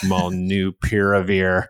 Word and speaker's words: molnupiravir: I'll never pronounce it right, molnupiravir. – That's great. That's better.